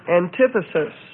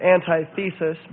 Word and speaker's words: antithesis: So the next one is antithesis. Some pronounce it antithesis, but I'm of the opinion that antithesis is the best way of pronouncing it.